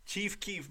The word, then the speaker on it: Chief Keef: Chief Keef, bro. Pronounce Chief it. Keef. Four letters. Chief Keef. – Not Chief Keef. – There you go.